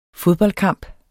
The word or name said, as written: fodboldkamp